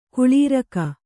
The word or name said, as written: ಖಲೂರಿಕೆ